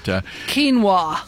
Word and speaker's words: quinoa: But quinoa. – Quinoa. – It's spelled Q-U-I-N-O-A. Yeah.